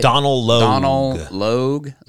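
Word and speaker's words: Donal Logue: Donald Logue.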